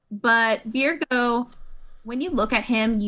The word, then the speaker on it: Byregot: And if you want to look him up, it's Byregot. – Not Byregot? – It's actually pronounced Birgo.